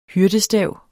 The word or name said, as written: hyrdestav